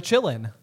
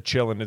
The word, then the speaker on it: Qilin: Or the Chillin. – Or the Chillin. – Yeah, which is spelled Q-I-L-I-N. – Yeah. – The Chillin, that's like the little horse dragon thing that can tell whether or not you're pure of heart.